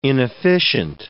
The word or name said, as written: inefficient